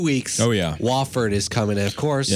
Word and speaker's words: Wofford: Wofford. – Okay. – Wofford.